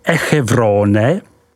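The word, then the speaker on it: Échevronne: Côte de Beaune, vyslovko du Bon, se rozkládá od hranic obce Echevrone, vyslov Echevron, po Chagny, vyslovuj Šaní.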